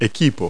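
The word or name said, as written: equipment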